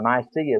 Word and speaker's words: Nicaea: He gathered them at a place called Nicaea, Nice. And there at Nice, or Nicaea as some people pronounce it, there was a great conference among the early church leaders.